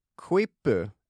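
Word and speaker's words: quipu: Ordet kvippu, är det så vi väljer att uttala det? Vad tror du? – Ja, det tycker jag, och det betyder då knut.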